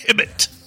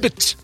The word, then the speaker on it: Hibbett: That right there is Brian Hibbett. – Hello! – Oh, the H is silent. – I like the H in there. You know, it's kind of fun for some reason. – Hibbett. – Hibbett.